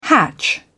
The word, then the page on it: hatch: British English